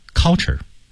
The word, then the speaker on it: culture: 比如说文化这个单词 culture 啊，很多人这么读，其实口型不到位，到位的就不读是 culture 了，读成 culture culture American culture，所以舌位唇齿没到位之前的音不可能到位啊。